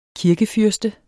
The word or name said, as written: KIRKEFYRSTES